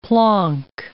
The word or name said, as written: plonk